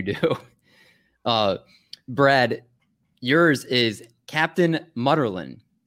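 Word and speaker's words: Munnerlyn: Mutterlin, yes. – Mutterlin. Captain Mutterlin. M-U-N-E-R-L-E-I-N.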